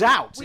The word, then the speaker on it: zout: And that's zout. – We have to check. – How is that pronounced then? – Zout. Favorite candy.